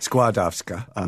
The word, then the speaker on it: Skłodowska: How do you pronounce it? – Skłodowska. Uh-huh.